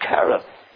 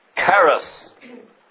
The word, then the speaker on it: Cherith: We would all agree that Cherith, or as people today pronounce it, Cherith, but the Bible pronunciation is Cherith.